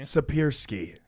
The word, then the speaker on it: Siepierski: I got your name right. – It's close enough. – Where was it? – It's Aaron Siepierski. – Siepierski.